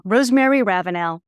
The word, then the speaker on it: Rosemary Ravinal: So, Rosemary Ravinal.